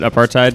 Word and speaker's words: apartheid: Is that how you pronounce it? Apartheid? – Apartheid, yeah.